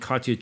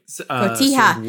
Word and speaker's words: cotija: So it's salsa roja shredded chicken on rustic bread with jack and cotija cheeses. I can never say it right. – Cotija.